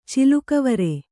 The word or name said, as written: ಚಿಲುಕವರೆ